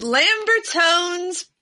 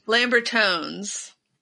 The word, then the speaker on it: Lambertones: It's Lambertone's pickups. Yes. – Are you trying to figure out how to pronounce that incorrectly on purpose? – Lambertone's. Lambertone's, not Lambertone. It's with the S.